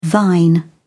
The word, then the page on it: thine: British English